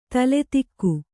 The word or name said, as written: ತಲೆದೂಕ